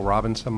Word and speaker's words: Robinson: My name is Michael Robinson. My last name is spelled R-O-B-I-N-S-O-N.